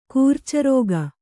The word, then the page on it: ಕರ್ಚೂರಕ: Kannada